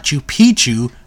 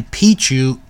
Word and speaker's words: Picchu: For most speakers of English or Spanish, the first C in Picchu is silent. So keep that in mind.